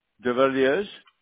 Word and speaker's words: Devilliers: Devilliers. D-E-V-I- L-L-I-E-R-S. – Okay. De Villiers? Is that how you pronounce it? – Yes. – Okay. D-E-V-I-L-L-I-E-R-S, correct? – That's correct, okay.